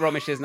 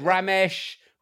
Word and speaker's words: Romesh: People get Romesh's name wrong a lot, but I have never heard him be rashed off. Rash. – Rash. – I've had Ramesh, Rajesh, Prajesh, yeah, Rajesh. Rash.